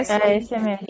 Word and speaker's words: ASMR: Como é que fala? Ashmir? É, é, ASMR? Ah, é ASMR.